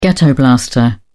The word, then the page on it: ghetto blaster: British English